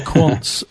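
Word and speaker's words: Quandt: Well, you're talking here about the Quants, I think, is I mean, certainly how it's pronounced here in the UK. – Sorry, I've got the German pronunciation. – Yeah, the Quants.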